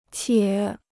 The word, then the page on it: 且: qiě